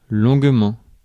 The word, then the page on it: longuement: /lɔ̃ɡ.mɑ̃/; France